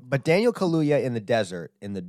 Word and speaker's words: Kaluuya: How the fuck do you say his name? Kaluuya. Man, poor man. I'm just butchering his name. – You know? – Daniel Kaluuya. I said it right at the end there. Kaluuya. It says two U's, man.